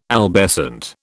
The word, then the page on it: albescent: British English